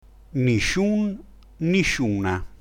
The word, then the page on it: nisciun: Dialetto